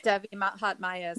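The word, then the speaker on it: Devi Mahatmya: She makes her first major appearance in Sanskrit culture in the Devi Mahatmaya. I think I said it right. – Yes, Devi Mahatmaya.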